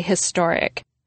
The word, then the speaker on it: historic: But it's a stretch, since the standard American pronunciation of historic is with the H sound. Historic.